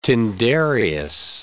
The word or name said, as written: Tyndareus